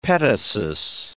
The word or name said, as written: petasus